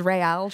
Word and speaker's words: Real: They are real drinks, but I pronounce them as Real drinks. – Real. – Like Real Madrid.